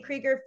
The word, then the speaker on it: Krieger: And I use Harmonie Krieger for all of the things, because that's my name.